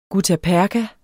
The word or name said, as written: guttaperka